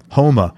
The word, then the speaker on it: Houma: Houma.